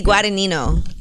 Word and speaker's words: Guadagnino: Vinny Guadagnino.